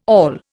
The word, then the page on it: awl: Indian